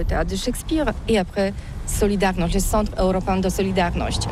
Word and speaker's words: Solidarność: Alors solidarité, en français on dit Solidarnosc, mais ici à Gdansk on ne prononce pas Solidarnosc. – On prononce Solidarnosc.